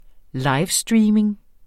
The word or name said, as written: livestreaming